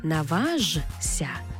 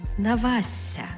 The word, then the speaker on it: наважся: Тобто пишемо «жся», але читаємо «зся». Зся. Тобто не наважся, а навася.